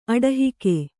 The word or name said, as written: ಅಡಹಿಕೆ